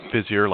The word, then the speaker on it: Visier: So we say that Visier, like busier with a V, although it's spelled with an I, for those who want to go look at our website.